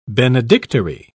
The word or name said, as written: benedictory